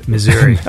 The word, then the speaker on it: Missouri: Am I saying it wrong? I say Missouri. How do you say it? – Missouri. – Okay, so I'm doing it right. – Some people like to say misery.